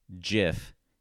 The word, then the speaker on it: GIF: And if you make up a word for a file format in the world, you get naming rights. And the creator of the GIF has said that you pronounce it GIF.